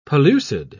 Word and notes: pellucid: British English

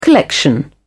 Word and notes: collection: British English

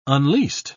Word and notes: unleased: British English

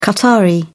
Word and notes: qatari: British English

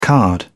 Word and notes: card: British English

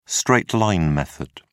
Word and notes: straight-line method: British English

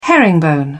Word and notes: herringbone: British English

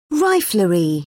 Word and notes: riflery: British English

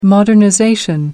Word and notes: modernization: American English

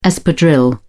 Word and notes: espadrille: British English

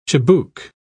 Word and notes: chibouk: British English

